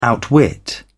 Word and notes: outwit: British English

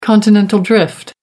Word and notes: continental drift: American English

